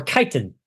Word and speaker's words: chitin: First adaptation is chitin, uh, or chitin, I guess that would actually be more accurately pronounced.